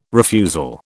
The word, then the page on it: refusal: British English